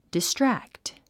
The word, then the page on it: distract: distrǽkt; ディストラクト